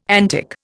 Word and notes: antic: Indian

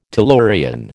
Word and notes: tellurian: American English

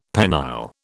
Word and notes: penile: British English

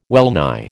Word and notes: well nigh: American English

